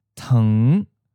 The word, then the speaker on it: thng: spelled H-N-G, meaning far, distant. Teng, spelled T-H-N-G, meaning sugar. Meng, spelled M-N-G, door. You might have noticed that in the last two words, there's a short U sound inserted before the N sound. So it becomes ng. This is common to make it easier to pronounce.